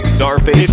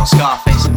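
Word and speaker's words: Czarface: Tsar face. – It's Czar. – Was it Tsar face, not Scar face? – I can't ever seem to say it right.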